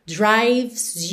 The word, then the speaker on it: drives: Like a heartbeat drives you mad, drives you, atenção que o E de drives também não é pronunciado, você vai do V direto pro S, drives you, uhum.